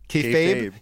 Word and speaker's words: kayfabe: You can't break kayfab. Did I use that word right? Is that the word? Did I mispronounce it? – Kayfabe. You can't break kayfabe.